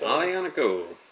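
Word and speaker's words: Aglianico: Alianico. – But it's spelled A-G-L-E-I-A-N-C-O. Has a G in it.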